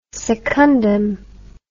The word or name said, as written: secundum